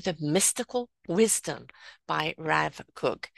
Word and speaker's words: Kook: Can you explain Avram Yitzchak Cohen Kook.